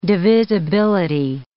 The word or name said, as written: divisibility